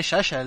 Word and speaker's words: Yaya: No se dice así. ¿Sabés cómo se sabés cómo le dicen en Francia? Porque es medio francés. – El... Touré, Yaya, le dicen.